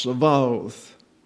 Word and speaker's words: Sabaoth: That's the Hebrew letter B. However, when the holim is absent, the pronunciation changes to a V, as in Victor. Therefore, the Hebrew title of the Lord is pronounced Sabaoth.